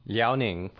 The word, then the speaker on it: Liaoning: Fushun, Liaoning province.